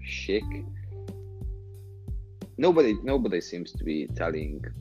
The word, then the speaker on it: chic: When you're trying to be chic. – Chic, yeah. That's yes. There. You and your pronunciations. – Well, it's French, so blame them.